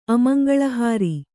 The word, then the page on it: ಅಮಂಗಲಹಾರಿ: amaŋgalahāri